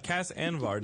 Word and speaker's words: Cas Anvar: And then Cas Anvar, did I pronounce that correctly? – You did, Anvar.